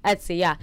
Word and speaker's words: Etsy: I also like, there's always eBay's and Etsy, I think it's how you say it. – Yeah, Etsy.